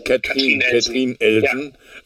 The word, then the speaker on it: Katrin Elsen: Aber bei mir ist aufgefallen, das ist ja besetzt von wie spricht man es aus? Katharina Elsen, ne? – Katrin Elsen. – Ja. Genau, aus Luxemburg, deswegen Katrin Elsen.